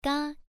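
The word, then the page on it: ga: tone 1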